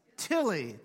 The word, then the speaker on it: Tilley: I didn't say that right, did I? What? How do you say that? Tilley, thank you. It's not the same guy that makes the hats, by the way.